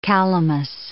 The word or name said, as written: calamus